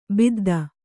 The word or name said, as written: ಬುದ್ಧ